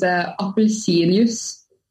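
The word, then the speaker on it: appelsinjus: correct pronunciation is apelsinius.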